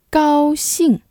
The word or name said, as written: 高兴